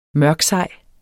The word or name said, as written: mørksej